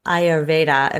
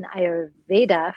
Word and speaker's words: Ayurveda: Tell us about the world of Ayurveda, if I'm even saying it right, and what it all means. – So Ayur means life, and Veda means knowledge or teaching or science.